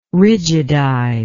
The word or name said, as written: rigidize